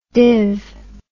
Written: div

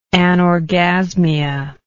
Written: anorgasmic

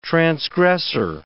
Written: transgressor

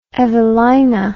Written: evelina

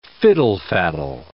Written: fiddle-faddle

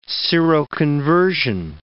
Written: seroconversion

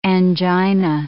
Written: angina

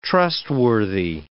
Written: trustworthy

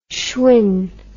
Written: schwing

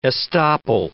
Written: estoppel

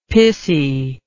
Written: pissy

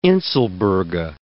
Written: inselberge

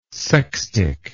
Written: sextic

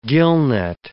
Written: gillnet

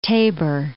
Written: Tabor, Mount

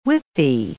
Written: whippy

